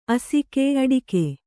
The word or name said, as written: ಅಸಗ